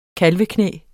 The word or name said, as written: kalveknæ